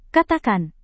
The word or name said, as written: katakan